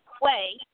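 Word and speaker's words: Quia: Quay, that's Q-U-I-A, and then Morgan, M-O-R-G-A-N-H-I-L-L, Quay Morgan Hill.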